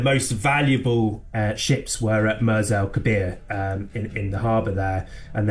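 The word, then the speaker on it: Mers El Kébir: For people who've never heard of this story, just tell us then what actually happened at, is it Mers El Kebir? Is that how you say it? – Mers El Kebir, yeah, in Algeria.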